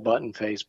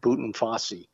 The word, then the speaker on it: Buttonface: He said, it's spelled Buttonface, but it's pronounced Buttonfossi.